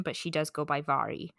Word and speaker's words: Mhairi: I have one of her books, and I've been saying Mary. Or Mhairi or something like that. – So, yeah. So it's Scottish Gaelic form of the name, so it's actually pronounced Mhairi.